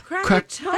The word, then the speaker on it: Krakatoa: The Scream by Edvard Munch features the man who is screaming based on a moment after the, and I don't think I'm pronouncing this correctly, Krakatoa? – Yeah, Krakatoa. – That's right.